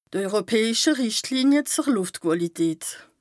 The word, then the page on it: europäische Rìchtlinie zer Lùftquàlität: Bas Rhin